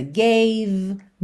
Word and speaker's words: gave: Gave, more, love, inside, have. Ou seja, essas letras E não são pronunciadas, ok? Então você sempre para na consoante anterior.